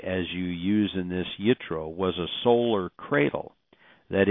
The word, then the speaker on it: Yitro: Now also what I found was this, quote, Midrashic literature asserts Jethro, Yitro, in other words, the J is silent or is utilized a hard Y sound, Yitro, converted to Judaism and called Gershel Emmet, which means convert to truth.